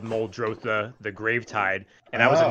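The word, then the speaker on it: Muldrotha: You can pronounce it any way you want, but I think the real terminology is Moldrotha. – Moldrotha.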